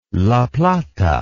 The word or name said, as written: la plata